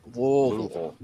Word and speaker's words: Louisville: You got to get it really in the back of your throat, like Louisville. – Louisville. Louisville. – Yeah. – The Cincinnati guys will go through Louisville.